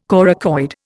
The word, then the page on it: coracoid: Indian